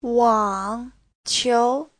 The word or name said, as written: wang3qiu2